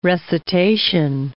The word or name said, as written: recitation